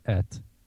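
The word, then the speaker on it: et (את): It's a particle that is spelled with an Aleph and a Tav. Aleph Tav, et, is how it's pronounced, that is placed in sentences before the definite direct object of the sentence.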